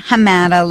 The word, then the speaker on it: Hamadeh: Samer Hamada, like what's the matter?